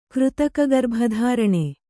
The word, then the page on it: ಕೃತಕಗರ್ಭಧಾರಣೆ: Kannada